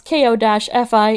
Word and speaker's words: Ko-fi: First of all, I filmed the first video to go onto Ko-fi, which I finally know how to pronounce. K-O-F-I. It is Ko-fi.